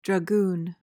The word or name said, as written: dragoon